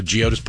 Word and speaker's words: Geodis: You've never been out to Geodis Park. First off, that's how you say it.